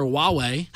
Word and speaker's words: Huawei: So moving on to my new favorite manufacturer, Huawei. I don't know if I pronounced that correctly. I really hope so. – You did.